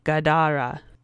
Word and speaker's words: Gadara: It was a three-syllable growl, low and mean, something like Gadara, with the accent on the second syllable,